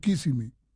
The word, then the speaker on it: Kissimmee: Así se pronuncia, ¿no? ¿Kissimmee o Kissimmee? – Kissimmee. – Kissimmee. Ah, bueno. ¿Quiere conocer Kissimmee, que se escribe K-I-S-S-I-M-M-E-E-E? – Correcto.